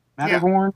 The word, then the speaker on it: Matterhorn: Matterhorn? Matterhorn? – Yeah, that's right.